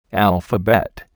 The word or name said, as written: alphabet